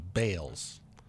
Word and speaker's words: Baals: Balls is spelled B-A-A-L-S. His family, since his passing in 1954, has changed the pronunciation of the last name to Bales.